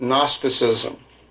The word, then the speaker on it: Gnosticism: They were Gnosticizing heretics, Gnostics, from the Greek word gnostikismos, but the G in English is not pronounced. So we say it Gnosticism.